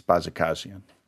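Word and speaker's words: Bajakajian: And I think that was very good pronunciation of that case, Bajikasian.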